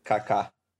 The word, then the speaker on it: Kaká: Kaká.